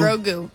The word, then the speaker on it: Grogu: Grogu.